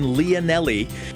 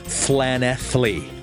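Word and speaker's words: Llanelli: And thanks to bat-eared listener and former RSC actor Matt Pearson, we will not be performing in Leonelli, but because in Wales the double L is pronounced as a THL, we will be performing in Flanethly. That's right, Flanethly. Apparently, that is the real pronunciation.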